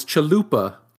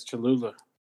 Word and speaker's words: Cholula: Says this sauce is called Cholula with two L's, and is saying, you're saying it wrong. It's Chalupa. – Yeah, it's Cholula. – Got him. – Well, no, he's good, because he's about to say, "Nah, I'm just joking. Said it wrong." – Yeah, yeah. Did really say it wrong? – No, yeah, that's right. Cholula.